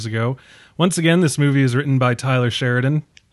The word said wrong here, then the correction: Taylor Sheridan: Taylor Sheridan, talking about mispronouncing people with T's in their name.